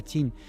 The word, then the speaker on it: Qin: que en realidad se pronuncia Qin, que es Qin Shi Huang.